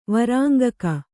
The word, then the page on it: ವೈರಂಗಿಕ: vairangika